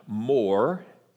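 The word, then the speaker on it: more (מור): It's pronounced more in Hebrew, and either murah or smyrna in Greek. There are two words for myrrh in Greek.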